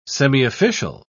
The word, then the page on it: semiofficial: British English